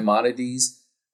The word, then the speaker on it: Maimonides: When you talk about that direct connection between Greek to Europe and from Islam to Enlightenment, are you talking about Maimonides, if I'm saying his name correctly? – Right, so Maimonides,